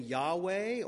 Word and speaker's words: Yahweh: It was considered so holy that they would never say it, and so the pronunciation was lost. We now approximate it by saying Yahweh, or the King James Bible translates it or transliterates it, if I can say this without offending anybody, incorrectly, as Jehovah.